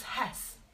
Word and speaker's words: Hesse: It's Hesse.